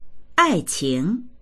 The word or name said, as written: ai4qing2